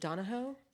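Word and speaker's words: Donoghue: Emma Donahoe. Is it Donahoe? – It's Donahoe. – Donahoe? – Donahoe, I think.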